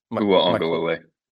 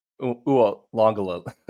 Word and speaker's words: Uiagalelei: So Mateo Uiagalelei, how do you say it? – Uiagalelei. – Uiagalelei.